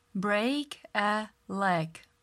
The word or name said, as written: break a leg